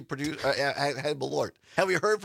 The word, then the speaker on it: Malort: So Malort is, and somebody, Julie or Jules in Mozanie, listening on WXCO, says, are you sure it's not pronounced Merlot? I actually looked it up, Jules, because I didn't know, and that's why I found that video of this guy reviewing it. And no, this is, it's spelled differently than Merlot. It's M-A-L-O-R-T, and it's pronounced Malort.